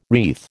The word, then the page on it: wreath: American English